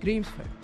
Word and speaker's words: Grímsvötn: He's monitoring the erupting volcano there, which, as you heard, is pronounced Grímsvötn. You almost don't even say the N at the end.